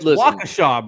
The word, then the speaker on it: Waukesha: He's it's Waukesha, bro. – Waukesha.